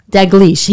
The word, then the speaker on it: Dalgliesh: Dalgliesh.